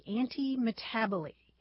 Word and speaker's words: antimetabole: It's spelled A-N-T-I-M-E-T-A-B-O-L-E, antimetaboly.